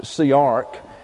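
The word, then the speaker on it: siach: It's S-I-A-C-H, pronounced siarch, and it means to rehearse or to go over a matter in one's mind.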